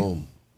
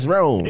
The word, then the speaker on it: Rome: Is Rome? – Like Italy? – Rome. – Yeah, like Italy. – All right, man. Why you say it like I know you, motherfucker? It's Rome.